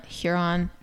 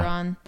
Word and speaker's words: Huron: I'm going to suggest that you listen to Harvest Moon, the Spotify studio version by Lord Huron. I don't know if I'm saying that right. Huron. – Yeah. – Huron. – Mm-hmm.